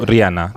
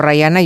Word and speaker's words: Rihanna: Se dice, yo, Rihanna. – así. Porque es que por Rayana por Rayana, yo no. No, no, no se dice Rayana.